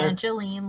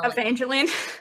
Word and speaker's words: Evangeline: Evangeline Lily. – Evangeline.